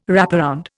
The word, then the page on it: wraparound: Indian